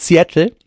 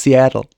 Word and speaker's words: Seattle: Es ist nämlich nicht Seattle, es ist Seattle.